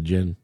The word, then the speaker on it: djinn: The djinn, the reason they say the djinn, because, am I saying that right? – Yeah. – The djinn, okay.